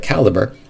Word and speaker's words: Calibre: Somebody corrected me on the pronunciation of that, since most people don't talk about it too much, but Calibre.